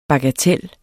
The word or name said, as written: bagatel